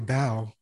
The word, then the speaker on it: Bao: I think for the longest time, if this like I think from kindergarten all the way to fourth grade, everybody just called me Bo, and I just thought that that's how you pronounced it. But I knew that at home, like my parents would call me Bao or Bao, and I just thought that's how, you know, coming to America, that's how people pronounce it.